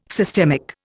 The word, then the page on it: systemic: Indian